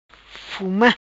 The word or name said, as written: Fuma